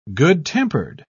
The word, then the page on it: good-tempered: British English